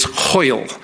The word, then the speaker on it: hwyl: It's hoil. Hoil. I'm going to ask you to say it in a minute. Hoil. It rhymes with loyal.